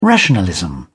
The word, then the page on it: rationalism: British English